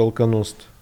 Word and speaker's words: Alkonost: Тебя знают как вокалистку группы Алконост, в которой ты... Я правильно произношу да. вообще? Да, да правильно. Окей, все.